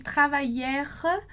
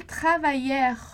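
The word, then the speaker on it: travaillèrent: Do mesmo modo, assim como no presente, a gente não vai pronunciar esse ENT ali no final da conjugação. Então, il travaillère, tá? Ali no final, com esse E mudo, justamente, ok?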